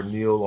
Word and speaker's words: Neal: And then on every other platform, literally, it's Rashard Neil. I'm not finna say every platform. I will tell you how to spell it, though, because people butcher it. That's R-E-S-H-A-R-D. Last name, N-E-A-L.